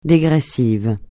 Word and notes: dégressif: French

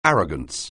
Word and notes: arrogance: anglais

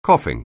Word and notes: coughing: anglais

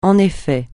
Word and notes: en effet: français

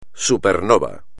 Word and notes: supernova: espagnol

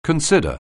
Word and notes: consider: anglais

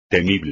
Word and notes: temible: espagnol

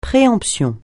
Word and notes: préemption: francais

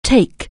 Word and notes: take: anglais

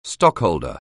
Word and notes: stockholder: anglais

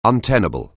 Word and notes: untenable: anglais

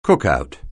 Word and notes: cookout: anglais

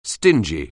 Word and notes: stingy: anglais

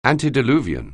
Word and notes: antediluvian: anglais

